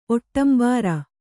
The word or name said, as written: ಒಟ್ಟಂಬಾರ